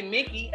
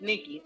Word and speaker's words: Nikki: But because people have mispronounced my name, I go by Nikki. – Yeah. – Who can fuck up Nikki? Make exactly. Like, say Mickey, and I'm like, no, it's Nikki.